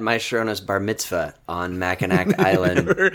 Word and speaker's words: Mackinac: We were up in Mackinac Island for vacation. Of course, back then we pronounced it as it should. – The Mac. – The PC Patrol dropped the hard K at the end.